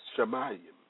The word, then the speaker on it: shamayim: The Hebrew word for heaven is shamayim, pronounced shamayim, meaning heaven, heavens, sky, visible heaven, as the abode of the stars, as the visible universe, the sky, meaning heaven, heavens, atmosphere.